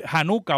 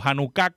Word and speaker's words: Hanukkah: Y la otra después del cierre del canon, durante el periodo macabeo, la fiesta de las luces, la dedicación, el el Hanukkah o Hanukkah, como usted lo quiera pronunciar, que se celebra en este tiempo, se va a celebrar en este tiempo.